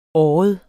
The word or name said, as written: året